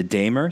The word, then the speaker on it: Damer: So from the mid-50s, the Unitarian Church at St. Stephen's Green, Dublin, was home to the, is it the Damer Theatre? – Yeah. – I pronounced it right.